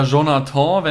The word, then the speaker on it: Jonathan: Und zwar geht es da um äh, Jonathan oder Jonathan, wenn er jetzt äh, französisch ausgesprochen wird, Klaus. Jonathan Klaus. Mhm. C-L-A-U-S-S.